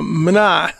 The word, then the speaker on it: mina: It's a mina, that's actually how you say it in Greek.